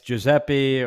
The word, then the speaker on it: Giuseppe: Yeah, it's Giuseppe, or it goes down to G, so it's a lot easier.